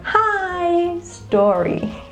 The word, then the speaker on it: history: So you don't stress on the second syllable, which is the ta, no, not his story, no. You stress with the first syllable, which is his. And then you go on, history, okay? This is the tip, okay? You go louder with the syllable that it is stressed. So the first syllable is stressed, you say it louder, okay? Hiss, and then you go softer and turn the volume down with the rest of the syllables.